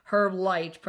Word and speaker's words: Herb Light: Launched this summer by Gordy Sang, co-founder of Chicago ad agency Quality Meat Herb Light, pronounced with the hard H, like your uncle's name, is being marketed with a tongue-in-cheek vintage ad campaign replete with pool parties, dimly lit bars, and bowling.